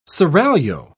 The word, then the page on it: seraglio: British English